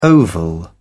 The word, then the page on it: oval: British English